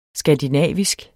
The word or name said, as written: skandinavisk